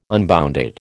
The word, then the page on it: unbounded: American English